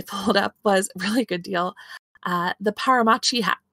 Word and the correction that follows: Pachimari: Paramachi? I'm saying it wrong. – Pachimari. – Paramachi? – Pachimari. – I typed it wrong.